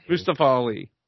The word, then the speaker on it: Mustafa Ali: Mustafa Ali? – Mustafa Ali, who became the number one contender.